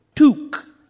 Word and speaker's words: toque: Toque.